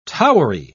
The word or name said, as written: towery